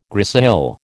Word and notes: grisaille: American English

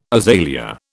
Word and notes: azalea: British English

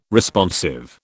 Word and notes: Responsive: British English

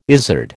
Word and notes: izard: American English

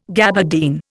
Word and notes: gabardine: Indian

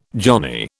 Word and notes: johnny: British English

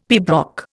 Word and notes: pibroch: Indian

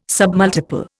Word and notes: submultiple: Indian

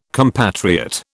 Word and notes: compatriot: British English

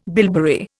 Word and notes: bilberry: Indian

